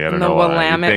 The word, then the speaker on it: Willamette: Willamette. yes. In Oregon, they say rhymes with damn it.